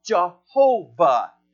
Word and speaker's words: Jehovah: That word Lord goes back to the Old Testament of the personal name of God, which is YHWH, the two ways to pronounce it. If you've heard the name Yahweh, or if you've heard the name Jehovah, that the name of Jesus that will confess that Jesus is Yahweh.